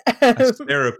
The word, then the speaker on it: Asterope: I just moved all of my ships, but with the exception of the ones that are Thargoid Hunters that live in Asterope, or Asterope, as it should be pronounced. – Asterope is the correct pronunciation.